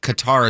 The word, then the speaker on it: Qatar: And also he says, he or she, doesn't say, Qatar is also pronounced like guitar.